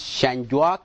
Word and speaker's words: Shangguak: By the way of introduction, my name is Shangguak Deng. It's actually pronounced Shangguak. So, but as you see, it's written Shangguak.